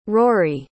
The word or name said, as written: ruaidhrí